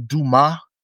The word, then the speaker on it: Dumas: I said, but it's actually Dumas. The S is silent for some of y'all who can't speak grammatically correct.